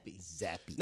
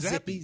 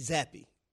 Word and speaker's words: Zappe: That's Zappe. Zappe. Not Zappe, Zappe.